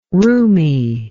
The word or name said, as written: roomie